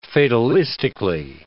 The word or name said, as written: fatalistically